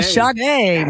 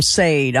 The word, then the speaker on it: Sade: Yeah, Sade, man. Yeah, exactly. Yeah, I said it wrong on live on radio. I called him Sade. I'm like, oh, I never lived that one down. So, yeah, Sade.